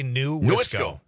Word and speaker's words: Nuisco: I always want to say N-E-W-I-S-C-O or something, so it's Nuisco. – Just say New Wisco.